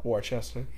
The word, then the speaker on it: Worcester: But yeah, that was when I also learned to pronounce Worcester and not – it's not how it's spelled. – Worcester.